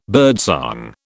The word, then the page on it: birdsong: British English